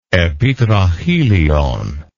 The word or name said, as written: epitrachelion